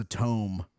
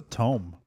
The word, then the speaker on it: tome: What's a tome? – That's what we're looking at him right there. – Did you just mispronounce tomb? – No. Nope. Tome. Tome.